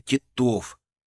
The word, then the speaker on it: Титов: Ударение на второй слог, сказал я. Титов.